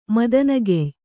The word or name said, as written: ಮದನಗೆಯ್